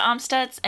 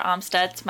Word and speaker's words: Amstutz: I'm Sandra Omstutz, and you can find me on all social platforms at Sandra Omstutz.